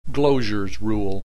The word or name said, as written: Gloger's rule